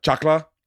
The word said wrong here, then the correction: chancla: Chancla, yeah.